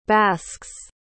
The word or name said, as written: basks